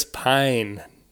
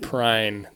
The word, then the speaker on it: Pine: Dog, it's Chris Pine, not Prine.